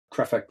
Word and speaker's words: Kreffer: So I have a blessing and a curse with my surname, Tom Kreffer. Kreffer, K R E F F E R.